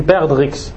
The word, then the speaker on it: perdrix: Donc ils sont de la forme, la trace qu'il a dans le dos, elle ressemble à l'œuf d'une perdrix. En arabe, il a des points. Il a des. Ah, on ne prononce pas le X. Perdrix.